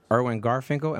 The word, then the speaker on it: Irwin Garfinkel: And this week, we have in the studio with us Professor Irwin Garfinkel.